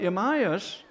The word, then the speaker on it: Elymas: But Elymas, it's pronounced, the sorcerer, for so is his name by interpretation, which stood them seeking to turn away the deputy from the faith.